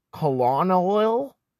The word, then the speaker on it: colonel: Colonel's a great one cuz it's spelt like colonel and it's like but we all know how to pronounce it colonel, you know?